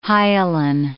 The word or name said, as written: hyaline